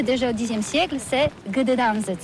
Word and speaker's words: Gdańsk: Alors d'abord, comment prononce-t-on bien? Ça s'écrit G-D-A-N-S-K. On dit Gdansk ou Gdansk, on prononce le G ou pas? – Ah oui, c'est pas très facile pour des étrangers, mais remarque bien que la langue polonaise, c'est la langue où on prononce chaque lettre qu'on écrit. C'est pourquoi on dit Gdańsk.